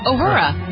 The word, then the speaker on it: Uhura: Uhura? – Uhura.